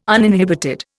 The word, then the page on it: uninhibited: Indian